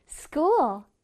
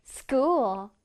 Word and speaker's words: school: school. 학교라는 단어인데요. 여기서는 ch 발음을 유의해 볼게요. 크 소리가 나고요. 그 다음에 oo는 우 발음을 내줘야 돼요. 해볼게요. school.